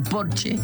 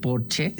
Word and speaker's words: Porsche: O decir que tengo un Porsche en lugar de decir tengo un Porsche, porque en alemán Porsche se dice Porsche porque todas las es al final se pronuncian, como Marlene Dietrich, por ejemplo, que todo el mundo dice Marlene, pero es Marlene.